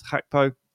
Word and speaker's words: Gakpo: This is Cody Gakpo, but we found out recently that he pronounces his name as Gakpo.